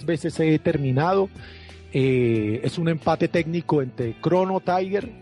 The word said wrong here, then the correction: Chrono Trigger: Chrono Trigger realmente, mal pronunciado Trigger, Chrono Trigger y Mario 64.